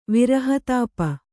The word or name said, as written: ವಿರಹತಾಪ